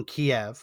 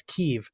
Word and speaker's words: Kyiv: The mainstream media shift from calling it Kiev, which has always been the American kind of way to pronounce it, to now pronouncing it as Kyiv, because that's how the Ukrainians pronounce it, which is fine.